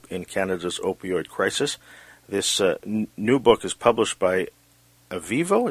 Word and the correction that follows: Aevo: is that how you pronounce it? – It's AVO, yeah. AVO.